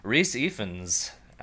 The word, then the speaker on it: Rhys Ifans: So Rhys Ifans, I did look it up. That is how you pronounce it.